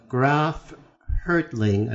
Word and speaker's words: Hertling: Graf Hertling, I believe that's how you pronounce his name.